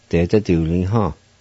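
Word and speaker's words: detsaduliha: De za du li ha. De za du li ha. De za du li ha.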